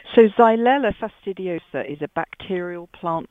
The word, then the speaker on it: Xylella: And well, I pronounce it Xylella. It's spelled X-Y-L-E-L-L-A, but I'm sure your pronunciation is fine as well, Peter.